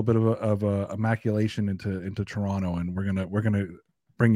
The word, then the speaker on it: Toronto: We don't pronounce the second T. We say Toronto.